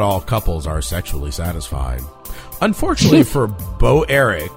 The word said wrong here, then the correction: Broerick: Bro Eric. How would you pronounce that? Would you pronounce that Bro Eric? Thank you. B-R-O-E-R-I-C-K. Bro Eric.